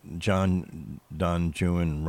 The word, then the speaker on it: Don Juan: Don Juan rhymes with ruin, right?